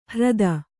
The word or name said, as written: ಹ್ರದ